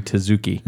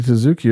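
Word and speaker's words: Tzuke: Judy Zook, it says. Judy Zook. – Judy Zook. No, it's Judy Tezuki. – It's Judy Tezuki.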